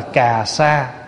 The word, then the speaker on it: cà sa: Cái áo này tiếng Phạn là ca sa gia, chúng ta đọc là cà sa, nghĩa dịch âm.